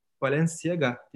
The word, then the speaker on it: Balenciaga: 1995 yılına yakın bir arkadaşının teşvikiyle üniforma sektörüne ilgi duymaya başladı ve bunun üzerine yaptığı fizibilite çalışmalarıyla beraber Paris'e Balenciaga diye okunuyor sanırım böyle. Evet. – Balenciaga firmasına görüşmeye gitti.